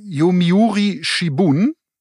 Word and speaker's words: Yomiuri Shimbun: Nee, Yomiuri Shimbun.